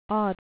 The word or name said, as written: aught